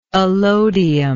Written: alodially

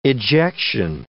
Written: ejection

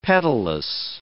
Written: petalous

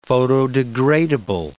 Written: photodegradable

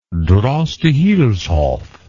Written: droste hulshoff

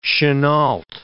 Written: Chennault

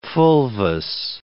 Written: fulvous

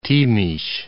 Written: Timis

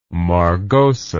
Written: margosa

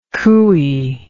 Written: cooey

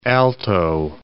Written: Alto Parana

